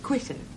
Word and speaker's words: Quito: Quito.